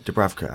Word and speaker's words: Dubravka: Am I saying that right? Dubravka. – How Dubravka, are you saying that? Yeah, yeah Dubravka. Dubravka, yeah saved by him.